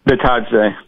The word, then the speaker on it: Bitadze: Bitadze.